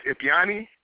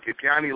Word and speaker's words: Ipyani: Yes, my name is Ipiani. I'm on Facebook, Ipiani Lockhart. You spell that I-P-Y-A-N-I.